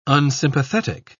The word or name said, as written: unsympathetic